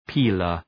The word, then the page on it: peeler: ‘pi:lər